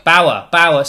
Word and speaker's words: Bower: Bower.